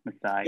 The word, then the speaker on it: Masai: I think it's Masai? Masai? Am I getting that right? – Right? Yeah. Masai. Yeah.